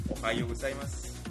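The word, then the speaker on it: Ohayo gozaimasu (おはようございます): Y bueno, pues ahora vamos a otro tiempo del día, y en este caso es buenos días, que se pronuncia Ohayo gozaimasu.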